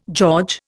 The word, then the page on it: George: Indian